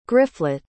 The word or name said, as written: gryfflet